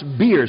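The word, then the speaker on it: bier: Now, the word is pronounced beer. It's not pronounced buyer.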